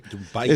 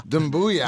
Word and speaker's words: Doumbouya: Doumbouya. – It's Sekou. – Sekou. – Remember Stuart Scott? Booyah? Yeah. – Booyah. – That's how it is. It's Doumbouya. – Doumbouya. – Doumbouya.